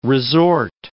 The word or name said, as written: resort